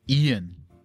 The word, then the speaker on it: Ian: Ian. Yeah, I-A-N, Ian. – I-A-N, Ian.